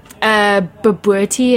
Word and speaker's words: bobotie: So tell me about Boris sausage or biltong, or how do you pronounce B-O-B-O-T-I-E? – Babuerti is the dish, that custard thing.